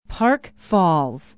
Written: park falls

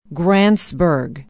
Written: grantsburg

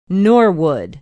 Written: norwood

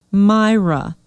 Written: Myra

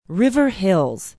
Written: River Hills

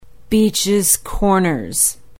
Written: beachs corners

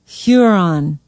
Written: huron

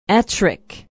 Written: Ettrick

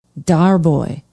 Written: Darboy

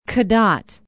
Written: cadott

